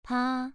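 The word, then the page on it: pa: tone 2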